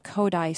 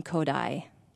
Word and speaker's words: Kodály: up a summer program at Capital University where music educators can come and get Kodály certification.